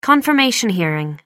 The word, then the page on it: confirmation hearing: American English